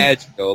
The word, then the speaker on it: Maggio: Maggio? Is that how you say it? Maggio NBA? – Maggio, like Joe DiMaggio, Maggio.